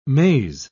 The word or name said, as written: maze